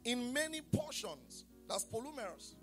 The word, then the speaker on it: polumeros: The Greek word for sundry times there is polumeros. I'll spell that for you. P-O-L-U-M-E-R-O-S. P-O-L-U-M-E-R-O-S.